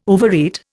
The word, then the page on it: overeat: Indian